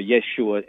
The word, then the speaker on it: Yeshua: and we know the traditional pronunciation is Yehoshua, or Yeshua in its shortened form, and I understand your point of view on those things.